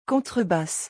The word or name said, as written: contrebasse